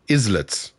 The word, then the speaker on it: islets: Is that how you pronounce it? Islets? Islets. Islets.